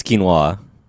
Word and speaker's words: quinoa: Is quinoa not right? – I believe it's quinoa. – Wait, say it again? – Quinoa. – Oh, because I'm saying the O, so quinoa. – For the listeners, Zell is nodding approval to my pronunciation.